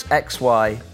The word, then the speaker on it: Xylaroo: She also mentioned during the talk the band Xylaro. I think that's how you pronounce it. Xylaro, perhaps. It's Xylaroo.